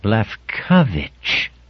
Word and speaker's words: Lefkowitz: And as he said it to himself, he placed its accent on the second syllable, Lefkowitz.